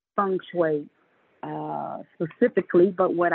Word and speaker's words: feng shui: Feng shui. Is that what it's, how it's pronounced in Chinese? – Yes.